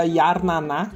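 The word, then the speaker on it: Jarnana: Mas a música que eu recomendo não é desse álbum, é a música que eu recomendo recomendo é uma música chamada Yarnaná, que se escreve Jarnaná com J, Yarnaná com J, é, que é do álbum Inquilinos do Mundo.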